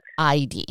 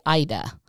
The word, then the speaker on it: Eide: So I think I used to say Ida instead of Eide. – Actually, you'd be correct because that would be like the Norwegian pronunciation, which is what this is, but the American version is Eide.